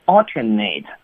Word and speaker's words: alternate: 啊，但是呢，大家要注意它的这个发音呢是有些变化的，比如说我们发声是 alternate，那就是刚才金老师所说的动词，对不对啊？